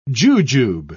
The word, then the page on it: jujube: British English